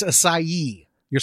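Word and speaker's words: açaí: I've always called it acai. Close. It's acai. You're supposed to say the ye at the end.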